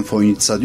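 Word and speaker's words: Fojnica: Ama çözemediğim şey, telaffuz olarak ben Foynitsa diyorum, öyle duydum. Fakat gelen misafirlerin hepsi o C'yi S olduğunu bilmiyor, TS olduğunu bilmiyor ve Foynica'ya işte gelmekten şeref duyduğum gibi şeyler zikrediyor. Merkeze bir yazı yazdım. Dedim ki Foynici olarak yazılan kelime telaffuzu Foynitsa şeklindedir, düzeltilmesi.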